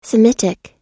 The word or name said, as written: semitic